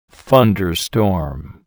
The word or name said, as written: thunderstorm